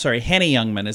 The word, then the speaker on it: Henny Youngman: Here's the quote from Henry Youngman. Henny Youngman is how you say it.